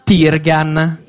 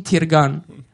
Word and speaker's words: Tirgan: Puria Jashan Tirgan. E questa in realtà è la pronuncia italiana, perché quella iraniana è ancora diversa. – E dici quella iraniana? – Quella iraniana è Puryo Jashan Tirgan.